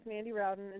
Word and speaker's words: Mandy Rowden: Well, like you said, my name is Mandy Rowden, and thank you for pronouncing it right, because most people don't.